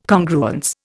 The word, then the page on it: Congruence: Indian